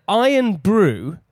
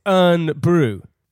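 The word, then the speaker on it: Irn-Bru: I found out that Iron Brew, yeah, you know Iron Brew? – Yeah. – It's not Iron Brew, it's Urn Brew.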